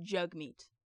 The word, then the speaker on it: Jagmeet: It's pronounced Jagmeet. It's spelled J-A-G-M-E-E-T. It is Jagmeet.